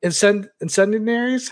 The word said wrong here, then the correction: Incendies: how do you pronounce it? – Incendies, I think. – Incendies, there you go.